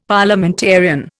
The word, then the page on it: Parliamentarian: Indian